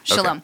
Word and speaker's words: shalom: Am I saying that right? It is shalom? – Yeah, shalom. Okay.